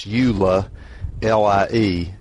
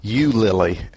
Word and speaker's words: Eulalie: That's Eula, L-I-E. I would pronounce that Eulalie, but I swear it sounded like the narrator was saying Eulily, and I thought that was kind of funny.